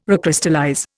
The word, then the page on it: recrystallize: Indian